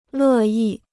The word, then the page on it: 乐意: lè yì